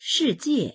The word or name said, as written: shi4jie4